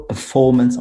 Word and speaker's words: performance: So management on the first syllable, performance on the second syllable, for example.